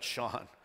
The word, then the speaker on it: shone: And the glory of the Lord shone round about them. My Canadian and British friends pronounce that Sean.